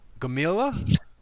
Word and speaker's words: Gomila: Gamilla? – Just Gamilla. Just Gamilla. Straight phonetics.